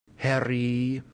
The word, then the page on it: heri: Latin